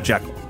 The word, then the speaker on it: Jekyll: Uh, in the Spencer Tracy movie, they pronounced it Jekyll.